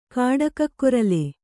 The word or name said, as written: ಕಾಡಕಕ್ಕೊರಲೆ